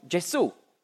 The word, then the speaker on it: Gesù: Darum sagt man auf Italienisch eben nicht Jesus, sondern Gesù.